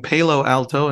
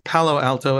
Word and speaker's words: Palo Alto: I showed up saying Palo Alto, and they all corrected me. So it's pronounced Palo Alto, everyone.